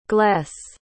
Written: gless

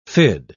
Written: fid

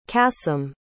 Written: qasim

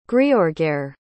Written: griorgair

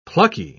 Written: plucky

